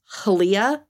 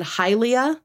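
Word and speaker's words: Hialeah: I've seen it pronounced Halea and Hylia.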